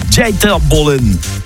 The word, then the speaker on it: Dieter Bohlen: Alors le prononciation, pardon. Dieter Bohlen.